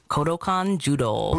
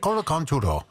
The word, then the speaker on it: Kodokan Judo: Kodokan Judo. – Oh, you say that right. I like the way you say that. Kodokan Judo.